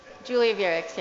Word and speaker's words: Julia Wirichs: Julia Wirichs, yeah.